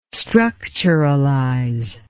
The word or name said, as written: structuralize